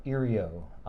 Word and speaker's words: IRIO: It was called IRIO, I-R-I-O.